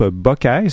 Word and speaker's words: Bacchae: On est pas mal dans les tendances style Riot Girls, punk, post-punk, avec le groupe Buckeye, c'est comme ça qu'on le prononce, de Washington DC. Je vous épelle le nom, B-A-C-C-H-A-E.